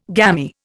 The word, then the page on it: gamy: Indian